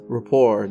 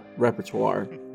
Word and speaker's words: rapport: it's pronounced rapport, not repertoire.